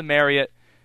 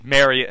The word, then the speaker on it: Marriott: Pie Guys Pizza and More, a great sponsor of this broadcast from the Marriott. Gosh. Darren Vaught was messing me up earlier, telling us actually the correct pronunciation, that it is Marriott instead of Marriott.